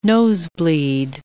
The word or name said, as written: nosebleed